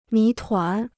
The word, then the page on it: 谜团: mí tuán